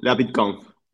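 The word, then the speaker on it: LaBitConf: How do you pronounce it? La Bitcoin. – The BitConf. La BitConf. Latin American Bitcoin Conference.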